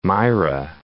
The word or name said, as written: Myra